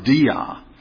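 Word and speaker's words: dia (διά): It's pronounced dia.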